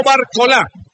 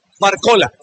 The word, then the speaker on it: Barcola: Y no Barcola, Barcola. Así es la pronunciación de estos dos astros franceses.